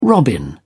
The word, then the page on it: robin: British English